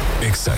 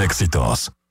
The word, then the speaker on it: EXA: Exa FM 89.9. No se dicen éxitos, se dice EXA.